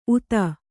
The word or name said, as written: ಉತ